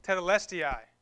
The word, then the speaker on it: Tetelestai: Tetelestai.